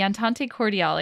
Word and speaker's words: Entente Cordiale: The Entente Cordiale, excuse my pronunciation, it's probably not correct.